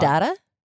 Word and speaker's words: Caudata: And then there's, how do you say it? I always want to say like the dog. – Caudata.